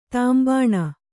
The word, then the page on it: ತಾಂಬಾಣ: Kannada